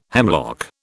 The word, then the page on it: hemlock: British English